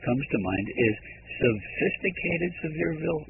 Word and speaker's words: Sevierville: Well, you know, the funny thing is, as I look at that word, and pronounce it again for me. – Sevierville. – Sevierville, which is, of course, not so severe.